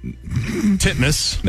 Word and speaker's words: Titmus: Pronounce it the way it's spelled. – Ariane Titmus. Titmus. – There you go. – Is that how you say it? – That is legitimately how you say it.